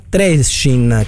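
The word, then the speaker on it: Trenčín: Questa forse è meno importante e mi riferisco alla Mitropa Cup Fiorentina Genoa Trescin, credo si pronunci così o giù di lì. – L'hai pronunciato in maniera splendida.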